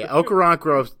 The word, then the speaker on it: Okoronkwo: Okoronkro is how I've been pronouncing it. You know, I, I think you nailed it there.